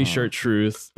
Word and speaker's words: Chartreuse: Chartreuse, heavy chartreuse, just a splash, like a little splash of absinthe.